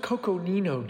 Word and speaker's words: Coconino: And I almost want to say it's Cocosino sandstone? – I'll leave that to you, dude. Yeah. – It's pronounced Coconino, Dave. Coconino sandstone.